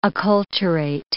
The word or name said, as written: acculturate